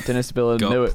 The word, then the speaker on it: Denis Villeneuve: We're talking about Denis Villeneuve. Can you pronounce his last name? – It's Denis Villeneuve. – Yeah, which Denis is Villeneuve. Not even close to how it's spelled.